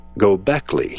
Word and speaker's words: Göbekli Tepe: And if you want to Google Göbekli Tepe, it's spelled G-O-B-E-K-L-I. Second word, T-E-P-E. Göbekli Tepe.